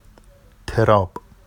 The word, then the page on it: terɑb: Vafsi